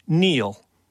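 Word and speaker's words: kneel: And Neil. – Okay. – The K, which was silent until I spelled it earlier in the clue.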